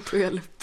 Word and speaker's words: Puyallup: Puyallup, alltså det här namnet är Jag kommer att säga fel på det. Det uttalas förmodligen inte så här. Men nej, Puyallup.